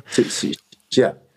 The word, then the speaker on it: Sicilia: nämlich, mit dem habe ich letztens über sein Buch Terra äh, Sicilia, Sicilia gesprochen, so spricht man es, oder? Sicilia? – Sicilia, ja.